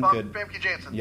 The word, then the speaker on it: Famke Janssen: Famke Janssen.